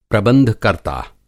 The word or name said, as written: प्रबंधकर्ता